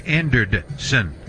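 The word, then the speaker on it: Anderdson: It's Anderson. – And it's one of those ones where that extra D doesn't necessarily change how you pronounce it,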